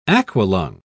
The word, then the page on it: aqualung: British English